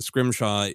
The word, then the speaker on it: Scrimshaw: And then most people are great about just Scrimshaw is spelled exactly as it's, you know, would be pronounced.